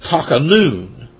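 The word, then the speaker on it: takanun (תַּחֲנוּן): And it's pronounced takanun. Takanun.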